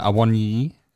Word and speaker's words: Awoniyi: Awoniyi. Pronunciation decent, obviously might be a little bit off. – He did well. That was a good pronunciation. I'm not Nigerian, but I imagine that sounds. That's how I pronounce it.